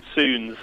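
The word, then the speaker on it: Suuns: Apparently it's Soons. I always thought it was Suns, but apparently Soons.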